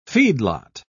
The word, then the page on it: feedlot: British English